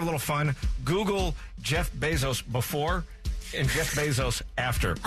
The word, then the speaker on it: Bezos: I read the other day that Jeff Bezos, is that how you say it, Bezos? – Yeah.